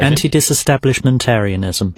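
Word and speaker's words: antidisestablishmentarianism: Anti-establishmentarianism. – Dunno. – I'm cooking under pressure. – Let's go with the official pronunciation. – Anti-disestablishmentarianism.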